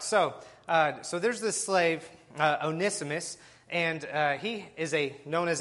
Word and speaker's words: Onesimus: I called him Onesimus, and then I made sure to double-check that because I was told I was wrong in my pronunciation. It's Onesimus, so there we are.